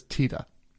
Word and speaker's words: Tiede: And the thing I noticed is that his pronunciation in the movie, Jack Black always says Tita, which the real Bernie does. But other people in the community will say either Tita or Titi, because his name is spelled T-I-E-D-E.